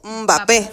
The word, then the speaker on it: Mbappé: él dijo que no se pronunciaba Mbappé, sino que es literalmente solo como Mbappé, Mbappé, Mbappé, Mbappé, con la M, ajá, que toda la gente lo pronunciaba mal.